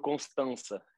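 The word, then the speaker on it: Constanța: Me corrija a pronúncia, por favor, porque eu tenho certeza que eu não falei corretamente o nome do clube. Mas é, como é que é? – É Vitoru Constança.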